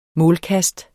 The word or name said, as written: målkast